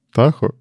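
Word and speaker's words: Tahoe: Как это такохи читается? Такое? – А не тахо? – Тахо? Может быть. Я у себя что-то вот, типа... А, тахоя, да, вот. Я все никак не загуглю, как оно правильно. Значит, тахо.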